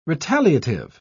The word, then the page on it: retaliative: British English